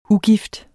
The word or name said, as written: ugift